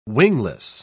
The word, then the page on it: wingless: British English